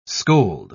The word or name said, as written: scold